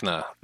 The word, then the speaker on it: Zipacna: Zipacna. It's Zipacna. They might have spelled it incorrectly.